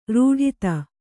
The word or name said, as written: ರೂಢಿತ